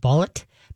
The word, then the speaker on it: balut: It's called, I think I'm spelling it right, or saying it right, bullet. B-A-L-U-T. Bullet.